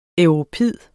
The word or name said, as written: europid